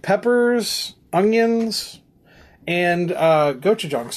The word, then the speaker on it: Gochujang: Gochujang. – Gochujang.